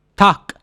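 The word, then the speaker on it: Tag: Tak is still written with a G, even though it's pronounced tak with a K.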